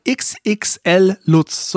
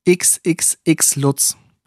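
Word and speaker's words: XXXLutz: Komplett falsch, man spricht es aus. XXX Lutz. Hä? Kannst du nochmal sagen? – XXL Lutz. – Genau, ein X zu wenig, ein L zu viel.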